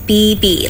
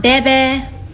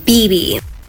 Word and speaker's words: Bebe: My name is BB, like two B's, not Bebe, BB.